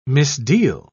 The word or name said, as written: misdeal